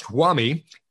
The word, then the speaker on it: Huami: I believe it's pronounced Huami. Yeah.